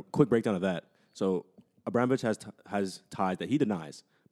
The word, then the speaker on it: Abramovich: Roman Abramovich. – Abramovich, yeah.